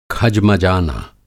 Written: खजमजाना

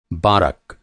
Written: बारक

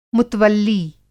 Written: मुतवल्ली

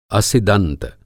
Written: असिदन्त

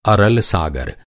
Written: सागर

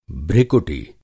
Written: भृकुटि

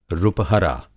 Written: रुपहरा

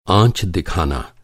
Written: आँच

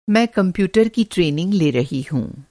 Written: ट्रेनिंग

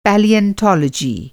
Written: पैलेन्टोलॉजी

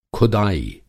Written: ख़ुदाई

